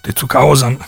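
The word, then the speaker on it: Tezuka Osamu: E, ma poi lui incontra Osamu Tezuka, o Tezuka Osam, come si dovrebbe pronunciare correttamente,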